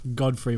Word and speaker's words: Gottfried: Godfrey, but it's spelt like Gottfried.